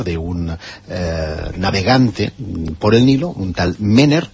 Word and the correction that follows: Merer: que o Merer, para ser exactos,